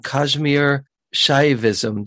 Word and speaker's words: Kashmir Shaivism: And along the way, he studied a master's in religion from the University of Sydney, specializing in Kashmir Shaivism. Did I say that right? – Yes, you did.